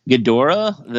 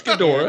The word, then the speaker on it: Ghidorah: Ghidorah, the three-headed monster. – That was pretty good. – It was close. – it's Ghidorah.